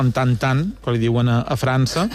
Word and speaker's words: Tintin: La N se nasalitza. Seria tan com Michelin, és a dir, no dirien Tintin con nosaltres.